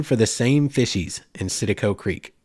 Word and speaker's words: Citico: And it had multiple spellings and multiple pronunciations throughout history and by different people, from Setico to Citico to Satapo. So just work with me. Citico Creek is how it's spelled. That's how I'm pronouncing it tonight.